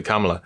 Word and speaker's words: Kamala: Okay, yeah, but it's actually Kamala.